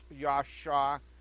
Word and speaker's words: yasha: And so the root word of Hosanna is a word that in Hebrew would be pronounced Yasha, which is the same root word from which somebody's name is derived.